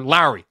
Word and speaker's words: Lauri: We'll see, I want to talk about Lauri because I do think... or Lowry. I'm trying to drill myself because I've noticed that I've been pronouncing it wrong. I'm trying to pronounce him properly.